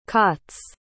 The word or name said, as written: cots